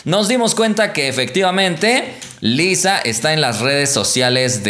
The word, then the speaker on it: Lysa: A fin de cuentas, ese asistente supuesto nunca fue oficial, pero Huawei parece que sí ha hecho oficial a este asistente llamado Lisa, o algunos me dicen que se debería llamar o pronunciar más bien Liza, porque tiene L-Y-S-A.